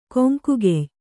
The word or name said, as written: ಕೊಂಕುಗೆಯ್